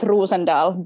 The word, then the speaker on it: Rosendahl: It's pronounced Rosendal, but yeah, close enough.